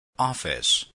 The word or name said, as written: office